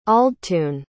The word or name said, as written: aldtun